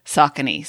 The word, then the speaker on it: Saucony: And they did say it's Saucony.